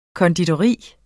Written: konditori

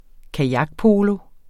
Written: kajakpolo

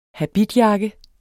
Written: habitjakke

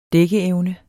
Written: dækkeevne

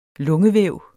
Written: lungevæv

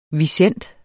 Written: visent

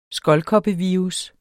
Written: skoldkoppevirus

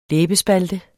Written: læbespalte

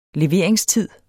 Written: leveringstid

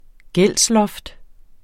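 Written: GÆLDSLOFT

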